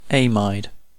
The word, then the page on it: amide: British English